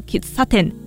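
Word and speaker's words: 喫茶店: 吃茶店在日文称作 Kitsaten，咖啡厅呢则念作 Cafe，光是在念法上就已经有差异了。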